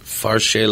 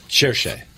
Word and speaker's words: Cherchez: I'm going to mispronounce it, but Farche la femme? – Cherchez.